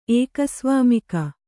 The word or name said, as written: ಏಕಸ್ವಾಮಿಕ